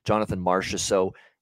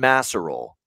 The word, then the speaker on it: Marchessault: John Masserol, as Don Cherry once called him. Yeah. We always love the old Don Cherry name pronunciation mistakes. And yeah, that was one of the classic ones.